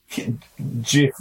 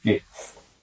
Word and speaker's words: GIF: What sound does graphics start with? – G. – G. G. Not a G. – Yeah, so it's GIF. – So, but there's a whole video here and it's pronounced GIF, not GIF.